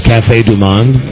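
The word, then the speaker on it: Café du Monde: The whole wait staff at this place, Cafe Du Monde, am I saying that right? – Yes.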